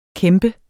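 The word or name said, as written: kæmpe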